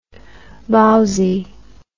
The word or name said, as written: bowsie